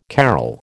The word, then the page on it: carol: American English